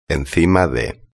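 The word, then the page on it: encima de: Spanish (Spain)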